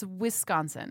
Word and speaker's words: Wisconsin: You say it as though it's pronounced W-E-S-K hyphen H-O-N-S-E-N. – That's how I say it because I'm from Jersey. – Wisconsin. – No, Wisconsin. – It's Wisconsin. Mm.